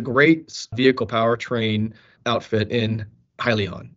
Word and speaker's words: Hyzon: So I did notice that you were entering into a partnership with Hyzon. I think that's the way it's pronounced. – Yep.